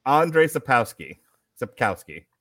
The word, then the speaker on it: Andrzej Sapkowski: Andrzej Sapkowski.